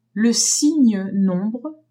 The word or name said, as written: signe nombre